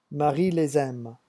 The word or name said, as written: Marie